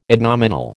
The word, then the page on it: adnominal: American English